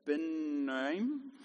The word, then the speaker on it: Abinoam: She sent for Barak, son of Abinoam, that's the correct pronunciation,